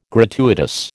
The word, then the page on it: gratuitous: American English